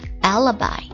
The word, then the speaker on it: alibi: 不在场证据或者借口托词这个单词 alibi Alibi, A-L-I-B-I, A-L-I-B-I, 读作 Alibi, Alibi, 三个音节 Alibi, Alibi, It's a noun, 是个名词，表示不在场，不在犯罪现场，证明或者借口或者托词。